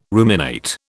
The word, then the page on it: ruminate: British English